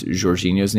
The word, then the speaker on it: Jorginho: And by the way, this is how I heard someone pronounce Jorginho's name the other day. It was Jorginho. Oh, it was Matt Law on The Telegraph. Yeah. Apparently, that's like the Portuguese way to pronounce it. But obviously, most of us are just Americanizing and Britishizing it and saying Jorginho.